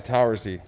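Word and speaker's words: Towersey: A.k.a. Chad Towersley? – S-E-Y, Towersy.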